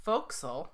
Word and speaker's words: forecastle: So, in case you were wondering, a forecastle, the term forecastle, pronounced fo'c'sle.